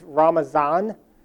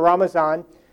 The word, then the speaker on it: Ramazan: Fasting during the month of Ramadan, unless they're from the Farsi-speaking world, it's Ramazan. And for some reason, Turks, who are neither Farsi-speaking or Arabic-speaking, took the Farsi pronunciation. So it's either Ramadan or Ramazan.